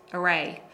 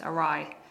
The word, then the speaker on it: awry: However, I just want to go back because I made a slight pronunciation error. That's really common. When we were talking in the first article about going off track or something being derailed, I said you can also say it went awry.